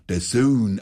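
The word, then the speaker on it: DAZN: Natürlich, man braucht kein Sky oder Dotzen. – Allmächt, heute wieder lauter Profis am Start. Das eine heißt nicht Sky, sondern Sky und das andere spricht man der Sohn aus.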